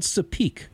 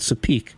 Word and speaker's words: Tsapiky: It's called Sapik. It's spelled T-S-A-P-I-K-Y. Just drop that Y at the end, Sapik.